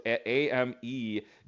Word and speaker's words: Amegadjie: It just looks a little bit intimidating because there's a D and a J for people. It's AmeGadJie.